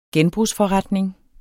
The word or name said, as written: genbrugsforretning